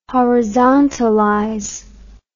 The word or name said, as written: horizontalize